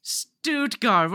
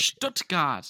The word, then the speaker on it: Stuttgart: I was thinking closer to Stuttgart. – Stuttgart. Can I bully you for a second? – Yeah. – Stuttgart. What are you, stupid American? Can't even pronounce things correctly.